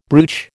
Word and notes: brooch: American English

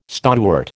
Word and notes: stonewort: American English

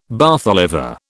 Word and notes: Bath Oliver: British English

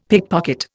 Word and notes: pickpocket: Indian

